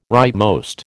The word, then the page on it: rightmost: American English